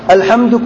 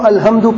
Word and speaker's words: الحمد: اکثر غلطی تلفظ کی کہاں ہوتی ہے؟ ح قاف کاف. زیادہ تر ہم عجم کے نزدیک جو اردو بولنے والے ہیں اس میں غلطی ہوتی ہے. الحمد کو الحمد پڑھتے ہیں، غلط ہے.